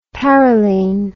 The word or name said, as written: parylene